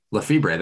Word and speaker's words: Lefebvre: Lefebvre, thank you.